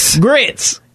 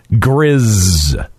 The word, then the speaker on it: Gris: Gris. – Grits. – Grits. – Oh, yeah. – No, it's Gris. I did not spell that wrong. Okay. – Is it Grizz or Grizz? – Grizz sounds Grizz. – G-R-I-S.